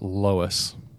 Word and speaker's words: Loess: Okay, according to the Google pronunciation, it's pronounced Loess, like Lois Lane, but it's L-O-E-S-S.